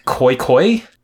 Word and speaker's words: Khoekhoe: Mike Richards pronounced it more like koi koi, Ooh.